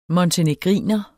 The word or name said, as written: montenegriner